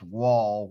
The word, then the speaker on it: Woll: Of course, there are two goalies in that conference whose name is pronounced Wall. One is spelled W-O-L-L at Boston College, and he's been having a pretty good year.